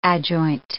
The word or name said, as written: adjoint